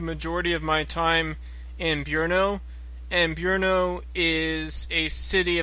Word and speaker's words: Brno: Brunn in German, which is like B-R-U umlaut N-N, and I think in Czech it's Brno, which is, if I'm pronouncing it correctly, which is B-R-N-O. Yeah. – That's right.